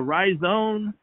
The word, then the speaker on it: rhizome: It's done by crushing the root, but the root is called a rhizome. That's how you say it. It's R-H-I-Z-O-M-E.